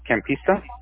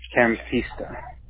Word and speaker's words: Campista: C-A-M. – Mm-hmm. – So it's Charlie Alpha Mike. – C-A-M. – I-S-T-A. Campista. – Got it. Pretty much exactly how it sounds. And then it's E-N-O-C.